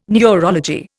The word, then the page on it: neurology: Indian